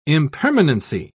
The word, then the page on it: impermanency: British English